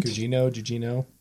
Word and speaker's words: Gugino: Gugino? Is that right? – Gugino.